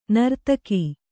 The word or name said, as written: ನಾರ್ಥಕ